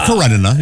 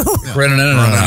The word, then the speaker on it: Karenina: It's Karenina. You're right. – Thank you. – Karenina.